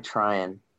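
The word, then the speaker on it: Treinen: Blake Talian. Who? He just signed back for the Dodgers. The reliever. – Oh, Tryon. – Tryon, yeah. Wow. – It's like the gum or, it's like the gum or whatever.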